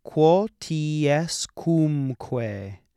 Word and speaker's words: quotiescumque: Hoc facite, quotiescumque sumitis in meam commemorationem. – One particularly challenging word in there. Quotiescumque. Quotiescumque. You could also pronounce it quotiescumque. It's a little more difficult that way.